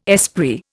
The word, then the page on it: esprit: Indian